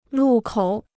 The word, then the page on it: 路口: lù kǒu